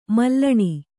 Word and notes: ಮೂಲಾಣು: mūlāṇu

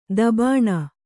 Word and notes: ತೀಬನು: tībasu

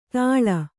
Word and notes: ತಾಳ: tāḷa

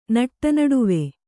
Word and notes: ನಟ್ಟನಡುವು: Kannada

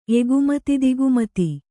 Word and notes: ಎಗುಮತಿದಿಗುಮತಿ: egumati digumati

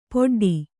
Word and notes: ಪೊಡ್ಡಿ: poḍḍi

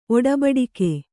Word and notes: ಒಡಬಡಿಕೆ: oḍabaḍike

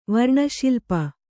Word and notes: ವರ್ಣಶಿಲ್ಪಿ: varṇa śilpi

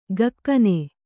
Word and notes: ಘಕ್ಕನೆ: kannada

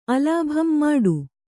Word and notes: ಮುಟ್ಟ: Kannada

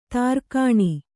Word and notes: ದೊರೆಗಾಣ್: Kannada

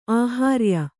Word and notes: ಆಹಾರ್ಯ: āhārya

